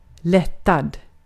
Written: lättad

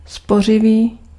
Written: frugal

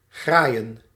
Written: arracher

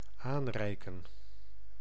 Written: pass

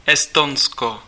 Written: Estonia

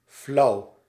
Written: lukewarm

